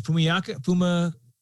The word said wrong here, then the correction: Fama: Oh, Ryan Fama. Yeah, Ryan.